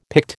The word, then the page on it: Pict: American English